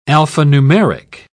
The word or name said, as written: alphanumeric